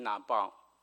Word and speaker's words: Nabal: 在希伯来文这个鱼丸在希伯来字叫做 Nabal，念成 Nabal。